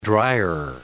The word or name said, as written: drier